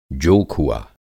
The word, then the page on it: जोखुआ: Hindi